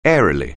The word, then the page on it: airily: English